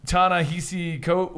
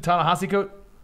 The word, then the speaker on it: Ta-Nehisi Coates: So what is Ta-Nehisi Coates? What is it? Ta-Nehisi Coates? – You actually were pretty close.